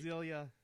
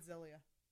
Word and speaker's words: Exilia: Because you were going to try to find out how to pronounce the X instead of making it a Z. – Exilia. – I would have said Zilia.